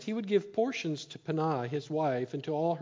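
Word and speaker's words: Peninnah: The name of one was Hannah, and the name of the other was Penah." That's how you pronounce it, actually, in the Hebrew.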